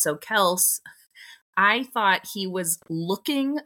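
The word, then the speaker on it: Kelce: Everyone says it's Kelsey.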